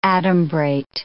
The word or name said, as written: adumbrate